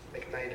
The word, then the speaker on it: McKnight: McKnight is spelled M-C capital K-N-I-G-H-T.